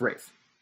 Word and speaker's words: Ralph: We're saying Ralph Fiennes, isn't it? Not Ralph Fiennes? – It's pronounced Rafe.